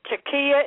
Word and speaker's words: taqiyya: And when they say these things, also they are practicing the taqiyya. Is that how you pronounce it that you talked about earlier, where they are supposed to lie? – That's right.